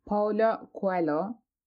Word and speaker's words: Paulo Coelho: Evet, yazarı Paulo Coelho. Yani böyle okunuyormuş. Ben böyle okunduğunu düşünmüyordum.